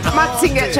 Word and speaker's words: Mazinger: Mazinger o Mazinger? Cioè, dove si mette l'accento? – Mazinger.